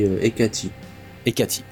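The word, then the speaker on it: Hecate: En anglais, c'est on dit Ekati Ekati, ok, d'accord.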